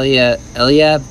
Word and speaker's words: Eliab: All right, now Eliab, his oldest brother, I guess that's how you say it. Yeah, that's it.